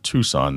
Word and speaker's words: Tucson: Tuxon, spelled Tuxon, is on purpose, because when you try to say the word Tucson, that's exactly how it's phonetically, you know, Tuxon.